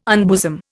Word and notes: unbosom: Indian

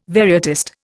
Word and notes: varietist: Indian

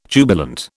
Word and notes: jubilant: British English

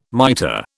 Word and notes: miter: British English

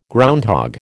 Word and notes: groundhog: American English

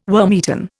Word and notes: wormeaten: Indian

